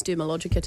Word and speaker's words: Dermalogica: I use Dermalogica too. – Is that how you say it? – Yep. There's no D in the middle, but anyway.